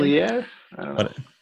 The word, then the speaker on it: consigliere: Then we got soldiers, then we got capos, then we got consigliers. I don't know if I'm saying that right. I'm not Italian. – Consigliere? – Then we got the underboss.